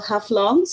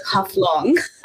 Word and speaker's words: Haflong: So the town I grew up is called Haflong. So it's basically Haflau. It's a small mountain, the original word. The British could not pronounce that, so they turned it into Haflong.